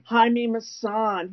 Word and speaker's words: Jaime Maussan: Jaime Maussan.